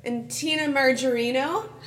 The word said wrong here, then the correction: Majorino: Tina Majorino? She's not margarine. – She's major.